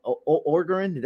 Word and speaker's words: Orgeron: Orgeron, that's how you say his name, Coach Ed.